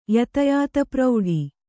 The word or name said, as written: ಯತಯಾತಪ್ರೌಢಿ